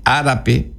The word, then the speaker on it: arape: Upe arape, ahí está otra vez el P, en ese día, ¿ves? En el arape, acento en la A, primera.